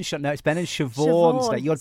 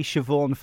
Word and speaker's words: Siobhan: Not Sean, I'm Siobhan. – You got to get your name right, if nothing else. This is it, isn't it? It's Ben and Siobhan. You're definitely Siobhan Fletcher.